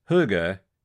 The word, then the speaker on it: hygge: First of all, let's start with how you say it. Pronounced Hygge,